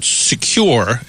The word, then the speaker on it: Secur: And it's from a company called Secure, and I hope I'm pronouncing the name right, S-E-C-U-R, so like secure without the E.